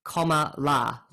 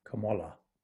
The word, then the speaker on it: Kamala: And just so everybody knows, she likes to always say that her name is pronounced like Kamala, like the punctuation mark. – Kamala. – Kamala. – Kamala. – So well, I actually think I said it incorrectly throughout, so I should have put that bit at the start, but it's comma, it's like Kamala.